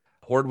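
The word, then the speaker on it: Horne: Horn with an E. The E is silent until midnight.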